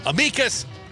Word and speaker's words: amicus: And the text line tells me amicus, either one, apparently okay for pronunciation.